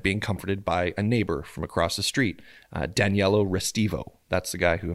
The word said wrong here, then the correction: Danilo: So it's Danilo. – Danilo? – Not Daniello.